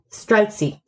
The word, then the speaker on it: Streltsy: Sorry, I need to just make sure I pronounce that correctly. Streltsy.